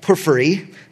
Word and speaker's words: Porphyry: In 300 AD, a quote-unquote scholar by the name of Porphyry, that's how we pronounce his name there, he taught that Daniel was not written in 600 BC, like tradition held, but in 50 BC.